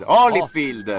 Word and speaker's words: Holyfield: No, ma che Holyfield? Lei che sa l'inglese. Holyfield.